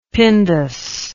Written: pindus

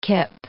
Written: kip

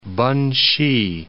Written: Pen-hsi

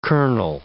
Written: colonel

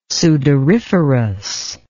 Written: sudoriferous

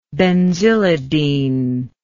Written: benzylidene

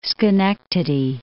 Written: Schenectady

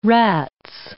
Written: rats

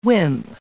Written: wimp

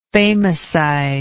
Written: famacide

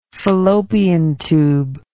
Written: fallopian tube

